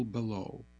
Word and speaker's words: Belleau: Michael Bellow, that's spelled B-E-L-L-E-A-U, Greenfield, Mass. Michael E. Bellow, age 79, passed Saturday, July 4th, 2026.